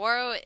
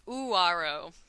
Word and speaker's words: Ouareau: Waro is spelt O-U-A-R-E-A-U. Waro in French. – Great. – Oh, so it's not spelt W-A-R-O? – That's right. It's not. It's pronounced Uwaro.